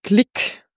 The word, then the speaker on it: Glück: Und im Amerikanischen wird sie dann auch, glaube ich, oft Glick ausgesprochen, Luise Glick.